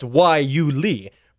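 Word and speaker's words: guayule: Now, it's pronounced Waiuli, but it's spelled with a G and Y and U and all sorts of strange things that don't make any sense.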